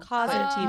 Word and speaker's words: Cosentino: Cosentino.